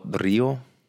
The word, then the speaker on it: potrillo: And the Spanish word for the foal or fowl, which I'm, I, potrillo. No, it's not. No, there's no. You don't have to roll your R. Potrillo, potrillo, potrillo. There we go.